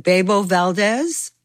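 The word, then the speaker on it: Bebo Valdés: Is it Bebo Valdez? – Mm-hmm.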